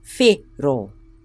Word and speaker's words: whero: Do you notice how we're rolling our R? Ro. So put together, it's whero.